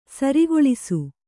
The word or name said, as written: ಸೂರೆಗೊಳಿಸು